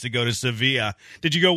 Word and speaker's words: Sevilla: A junkie says Sevilla junkie? – Is how it's pronounced. Almost like Savoia. Sevilla. There you go.